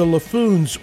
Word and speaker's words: Laffoons: And I have the Laffoons with me today.